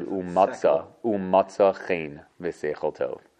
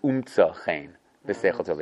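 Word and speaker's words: umtza chen v'seichel tov (ומצא חן ושכל טוב): The only problem is that you probably have pronounced it wrong your entire life. It's not your fault. You probably said, umatza chen v'seichel tov.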